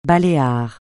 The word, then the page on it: Baléares: francais